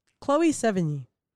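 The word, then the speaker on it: Chloë Sevigny: Chloe Sevigny. The pronunciation may be not necessary, but is that how you would say it, OT? – That's how I'd say it.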